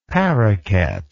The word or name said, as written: paraquet